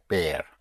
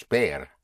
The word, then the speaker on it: ver: The word for to see is ver, spelled V-E-R, with vision, ver, ver, to see. The V in Spanish has the same sound as the B. V and B have the same sound. In other words, you pronounce the V with your lips, and sometimes it's a soft B, so it may sound like a V, but pronounced with your lips. So to see is bear, yes, like seeing the bear, bear, bear, bear.